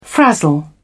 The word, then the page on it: frazzle: British English